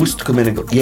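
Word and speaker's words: Ust-Kamenogorsk: You can correct my pronunciation. – Ust Kamenogorsk. Yes. – Okay. – Not Riga, R-I-G-A.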